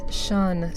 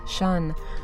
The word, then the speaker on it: Ssion: We started out the set with Sion, spelled S-S-I-O-N, pronounced like the last syllable of obsession or possession, Sion, with The Woman and vocals by Shannon Michaels from the album Fool's Gold.